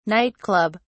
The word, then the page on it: night club: British English